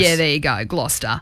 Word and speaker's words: Gloucester: Well, second was Gloucester. Is that how you say it? – Yeah, Gloucester.